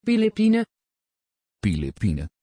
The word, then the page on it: philippine: Dutch